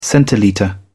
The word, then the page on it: centilitre: British English